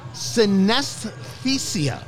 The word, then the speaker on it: Synesthesia: The pie, Synesthesia. S-Y-N-E-S-T-H-E-S-I-A. I believe I'm pronouncing it right when I say Synesthesia. This is everything that you ever dread in radio of not being able to pronounce.